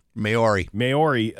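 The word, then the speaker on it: Māori: Maori, and non-Maori citizens.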